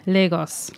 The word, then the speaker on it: Lagos: Well, it's Portuguese, so I'm sure Lagos is maybe how it's pronounced in Portugal. But it's Lagos, where we're from. – Lagos.